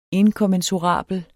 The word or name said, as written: inkommensurabel